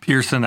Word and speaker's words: Pearison: Pearson, I silent.